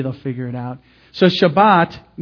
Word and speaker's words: Shabbat: Shabbat is how a Jew would pronounce this word.